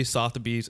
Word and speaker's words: Sotheby's: Like typically Sotheby's, and I pronounce that on point.